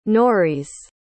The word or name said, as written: noreis